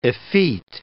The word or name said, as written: effete